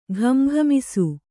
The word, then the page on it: ಘಮಘಮಿಸು: ghama ghamisu